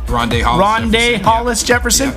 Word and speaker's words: Rondae Hollis Jefferson: Rondae Hollis Jefferson.